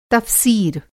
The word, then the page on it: तफ़सीर: Hindi